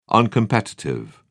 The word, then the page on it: uncompetitive: British English